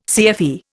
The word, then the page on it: CFE: Indian